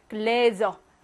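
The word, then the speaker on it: Gläser: 이럴 때는요, 똑같이 글라스이긴 한데, 얘는 스로 발음하고, 얘는 모음 앞에 있으니까, 글래저, 즈로 발음한다는 거.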